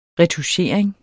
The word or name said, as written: RETOUCHERING